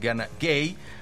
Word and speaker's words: Gana Gueye: Lamin Camara, porque también tiene su Lamin allá en Senegal, está en la media cancha junto con Gana Gueye, quiero decir, la pronunciación es Gana Gueye.